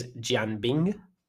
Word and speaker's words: Jianbing: The pronunciation of it is Jianbing, and it looks like an egg roll.